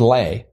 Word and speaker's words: Leigh: And so there was an EI in Vivian, and there was an EI in Leigh, or Lay. I can't remember what she was pronouncing. – I thought it was Lay. – Maybe it is Lay. Vivian Leigh, yes. Uh-huh, you're correct.